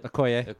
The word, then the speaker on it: Okoye: I would like to see one of the two, like Shuri or Okay, or how you say it? – Okoye. – Okoye.